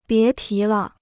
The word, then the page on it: 别提了: bié tí le